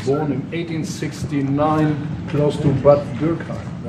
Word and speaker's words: Bad Dürkheim: Born in Bad Dürkheim, and Trump actually, I thought the pronunciation was quite good. He said Bad Dürkheim, but maybe we can listen to that.